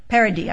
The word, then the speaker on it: Paradis: We need to give, how do you pronounce your name? – Paradis. – I gave you the French pronunciation, so I guess I was right.